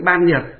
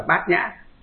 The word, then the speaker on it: bát nhã: Nhưng mà nhà Phật chúng ta thì đọc trại âm đi, chữ ban nhược đọc là bát nhã.